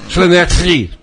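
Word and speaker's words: Llanelli: É Lianelli, não é? – Lianelli. É mais fácil.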